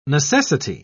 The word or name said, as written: necessity